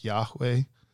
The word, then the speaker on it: Yahweh: but the approximate pronunciation is something like Yahweh. – Yahweh.